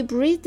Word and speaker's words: breath: He breathed a new life into the company and soon it was successful. 好了，名词呢就是去掉词尾的字母 e，读作 breath。